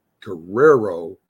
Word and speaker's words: Guerrero: It's Guerrero.